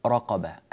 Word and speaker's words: رقبة: ننبه أيضا إلى ترقيق الراء في تحرير رقبة أو تحرير رقبة. الراء المضمومة هنا مرققة، الراء المضمومة هنا مرققة، الراء التي بعدها في كلمة رقبة مفخمة. بعض